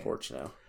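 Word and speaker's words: Prochnow: It's Jürgen Porchnow.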